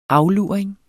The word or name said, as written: AFLURING